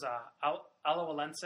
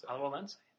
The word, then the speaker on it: Alajuelense: But yeah, so up next is Alajuelense. Pronounced right, actually. – Alajuelense.